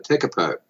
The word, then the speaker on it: Tekapo: We went on exercises down at Tekapo, and I think that's how you pronounce it.